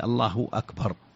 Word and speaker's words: الله أكبر: لا شك أن الصيغة هي الله أكبر، الله الهاء مضمومة لفظ الجلالة، وأكبر الهمزة مقطوعة، همزة قطع، همزة قطع، الله أكبر، هذا هو الأصل في الصياغة.